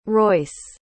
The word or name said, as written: royce